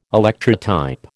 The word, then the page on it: Electrotype: American English